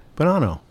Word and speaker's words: Bonanno: Please welcome to this episode of the St. Petersburg Foodies podcast, the owner/cheesemonger at Brooklyn South, Matt Bonanno.